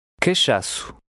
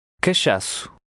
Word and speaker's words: Cachaço: The O at the end is faintly pronounced. – Cachaço. Cachaço.